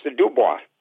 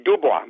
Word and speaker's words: Dubois: and then also a town which I now know is pronounced Dubois. – I'm sorry, Wyoming. – Dubois, Wyoming.